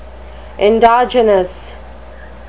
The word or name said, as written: endogenous